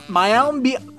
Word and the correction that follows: Mayim Bialik: I can never say I can never say her name correctly. I happen to admire and enjoy the work of Mayim Bialik.